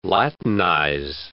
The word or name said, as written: latinize